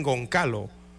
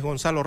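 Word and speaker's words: Gonçalo: algunos le dicen Goncalo, pero es Gonzalo Ramos.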